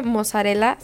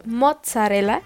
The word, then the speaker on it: mozzarella: Bueno, o sea, no se dice mozzarella, se dice mozzarella, porque en italiano dos zetas suenan aunque esté al principio y al final, así es la correcta pronunciación de las dos zetas en italiano.